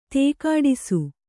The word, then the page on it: ದುಕ್ಕಡಿಸು: kannada